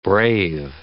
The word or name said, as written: brave